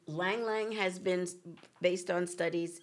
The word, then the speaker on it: Ylang Ylang: Y-L-A-N-G. Y-L-A-N-G. – Y-L-A-N-G.